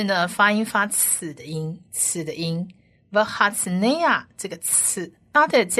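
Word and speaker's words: v'hatznea (והצנע): 再来往下一个字母是希伯来文字母的 nun 在这边呢发音是发 n 的音，而它下面的这个母音是长母音 a，所以是 nay v'hatznea 的 nay。那最后一个字母是 ayin，希伯来文字母的 ayin 不发音，但它下面的母音要发音，它下面的母音就是短母音 a，所以这整个字呢就是 v'hatznea 是谦卑的意思，谦卑的。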